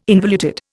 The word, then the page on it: involuted: Indian